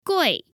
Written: gui4

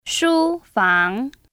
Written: ShuFang

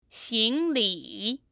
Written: xingli

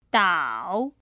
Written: dao3

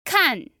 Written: kan4

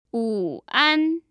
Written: wuan

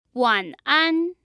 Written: wanan

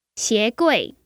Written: xie2gui4

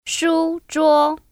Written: ShuZhuo

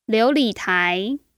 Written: liu2li3tai2

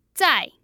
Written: zai4

